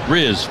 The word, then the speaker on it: Riz: I go, it's Riz, Fizz with an R.